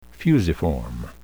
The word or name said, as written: fusiform